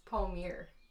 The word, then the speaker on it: Palmiere: So like Rachel said, my name's Kaston and actually it's Palmiere. – How did I say it? – Palmiere. – Palmiere.